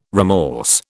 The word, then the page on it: remorse: British English